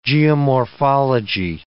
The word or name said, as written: geomorphology